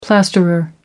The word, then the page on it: plasterer: American English